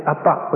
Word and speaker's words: Abba: Even with the right intonation, and exactly in the Aramaic, Appa, with the emphasis on the second syllable.